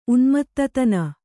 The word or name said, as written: ಉನ್ಮತ್ತತನ